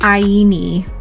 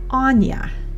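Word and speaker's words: Áine: And this spelling is A-I-N-E. And no, it's not Aini. It's Anya.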